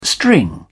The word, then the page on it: string: British English